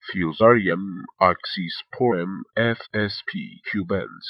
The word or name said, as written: Fusarium Oxysporum F.SP. Cubense